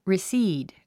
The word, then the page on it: recede: risíːd; リスィード